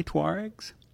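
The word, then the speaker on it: Tuareg: In addition to these small Islamic groups, insurgencies, jihadists, you've also got the Tuaregs. Who are they? Or did I say that correctly? Tuaregs? – You did. – That's actually where I was going to go to. – Yeah, just like the Volkswagen.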